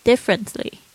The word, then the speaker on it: differently: The people 就是那边的人，there 就是呢那里，see you differently 看待你呢也是怎么样不同的，different differently 啊，那个在讲这个 t l y 结尾的这种字呢，通常 t 好像都被咬住不发音了，differently too 也是这样子看你好像不一样。